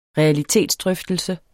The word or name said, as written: realitetsdrøftelse